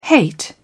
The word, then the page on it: hate: British English